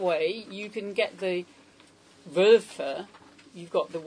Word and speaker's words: Wölfe: You have often, Wölfe has two syllables, whereas wolves has one syllable, right? So it might look the same length, but it's only got one syllable.